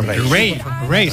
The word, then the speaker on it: Raye: Ray, se escribe Raye, Raye, con Raye, Raye, ya Raye, Raye.